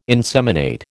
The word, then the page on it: inseminate: American English